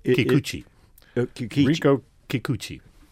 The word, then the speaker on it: Rinko Kikuchi: And there's a scene where this woman played by Rinko Kikuchi, I think is how it's pronounced, where she... – Rinko Kikuchi. – Thank you.